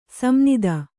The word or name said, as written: ಸಂನಿದ